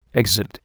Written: exit

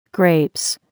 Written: grapes

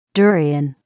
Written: durian

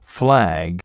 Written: flag

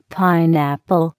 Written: pineapple

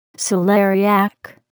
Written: celeriac

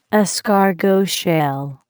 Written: escargot shell

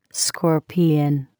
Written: scorpion

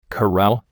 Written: corral

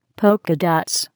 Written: polka dots

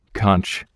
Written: conch

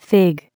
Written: fig

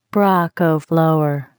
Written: broccoflower

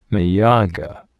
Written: miyoga

